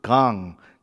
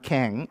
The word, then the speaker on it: Kang: It in Korean is pronounced Gang, just like not Kang, right? Gang, just like Gangnam Style, right?